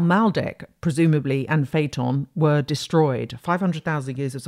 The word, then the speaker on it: Phaeton: The idea here, and then there's also Phaeton, Phaeton, you can Google Phaeton, which is spelt like Phaedra with the P-H-A-E, so Phaeton with T-O-N on the end.